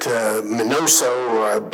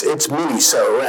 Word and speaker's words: Miniso: I used to pronounce it Minoso, but it's Miniso.